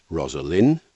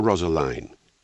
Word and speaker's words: Rosaline: For instance, how should we pronounce the last syllable of Romeo's first love? Is it Rosaline or Rosaline? It has to be the second, because of the rhyme in the friar's reply to Romeo, "Thou chidst me oft for lovin' Rosaline, for dotin', not for lovin', pupil mine."